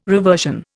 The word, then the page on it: reversion: Indian